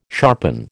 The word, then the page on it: sharpen: American English